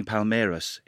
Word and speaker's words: Palmeiras: joining Palmeiras in 1999. – Good pronunciation of both Asprilla and Palmeiras there. You said Palmeiras a bit like Robbie Savage would say it, but that's okay.